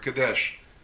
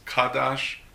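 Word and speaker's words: Kadesh (קדש): If we go back to the original Hebrew, we find that the word sodomite has been translated from the Hebrew word kadesh. Kadesh could also be pronounced as kadash or kadashe.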